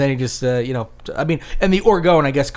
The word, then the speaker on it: orgone: He created a new concept known as orgone energy. – Orgone. Spell that. – O-R-G-O-N-E.